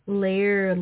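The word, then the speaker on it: Laire: On Facebook, she goes by Lair Leitner. I don't know if I'm pronouncing that right. It's L-A-I-R-E, Leitner, like L-I-G-H-T, Leitner.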